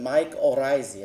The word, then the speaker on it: mycorrhizae: And if you have trouble remembering that name, if it's new to you, I just tell my students at Texas A&M University, just think of your Irish cousin mycorrhizae and you'll never forget it again.